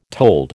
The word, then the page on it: told: American English